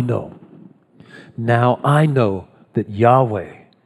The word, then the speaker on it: Yahweh: Although that name is certainly not pronounced in Hebrew as Jehovah, because they don't have a J sound in Hebrew. It's really closer to Yahweh.